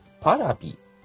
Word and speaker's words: Paravi: TBS、テレ東、ワウワウ、日経による映像配信、パラビ、 2018 年 4 月開始、 925 円ということで、定額の映像配信サービスがまた増えるみたいですよ。プレミアムプラットフォームジャパンは、来年 4 月に開始する映像配信サービスの名称をパラビに決定しました。 PARAVI と書いてパラビですね。